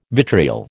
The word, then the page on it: vitriol: American English